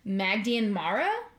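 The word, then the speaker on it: maighdean mhara: In Gaelic stories, they're described a lot of the times as Magdian Mara. I think is how you say it.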